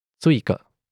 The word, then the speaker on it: Țuică: The last voiceless consonant is T. Imagine pronouncing T and S. S. Țuică. – Oh, that's the answer to how do you get drunk fast in Romania? – Yep. Țuică is a plum or wine brandy that can contain up to 60% alcohol.